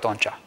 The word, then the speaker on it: Oncea: Și bun venit, îi spun nu în ultimul rând, domnului Cornel Oncea. – Bine v-am găsit. – Am pronunțat corect Oncea.